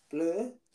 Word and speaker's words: Blau: So we've got Carl, forgive me for the pronunciation here, Carl Blau, B-L-A-U, Blur. – Sounds about right.